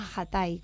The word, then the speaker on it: Ajatay: Decime cómo se dice, ¿Atay, el demonio blanco? – Hay diferentes pronunciaciones. Nosotros le decimos Ajatay, como pronunciándolo con J, porque es una palabra en wichí, en realidad.